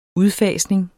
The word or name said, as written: udfasning